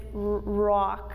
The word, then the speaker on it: ruach: One way breath in the Bible is known is, I'm gonna try to pronounce it correctly because it's in Hebrew, it's ruach.